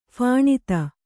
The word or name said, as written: ಫಣಿತಿ